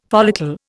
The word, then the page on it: follicle: Indian